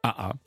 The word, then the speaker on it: AA: Alors déjà, comment ça se prononce? – AA, tout simplement. – Même en anglais? – Bah oui, AA, Move to Memphis. – Il n'y a pas le H muet? AA? – Non, je crois pas, c'est plutôt A.